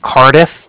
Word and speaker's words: Cardiff: I was calling with the Bank of Cardiff, and that's spelled C-A-R-D-I-F-F, as in Frank, so it's like car and then different.